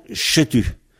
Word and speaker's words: setu: Alors, se tu, c'est qui S-E-T-U. S-E-T-U, se tu. Et nous prononçons « chetu ». Le « s » qui se prononce « che », c'est un phénomène commun à tous les dialectes, mais pas forcément pour les mêmes mots. Donc, nous, dans « setu », nous chuintons le « s » en « che », nous disons « chetu ». Mais ça s'écrit « setu ». Alors, « setu perak », « p-e-r-a-g » ou « k », moi, je mets G ou K selon ce qu'il y a après.